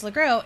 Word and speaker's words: LeGros: Is it James LeGros? Is the S silent? – It is silent, yeah.